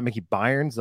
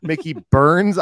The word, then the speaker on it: Mickey Burns: Apparently, I've been saying it wrong. So sorry, Mickey Burns. It's not Mickey Byrnes. Like I've been saying over and over for 6 months, like a freaking idiot. Mickey Burns Irish Pub.